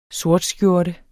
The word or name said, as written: sortskjorte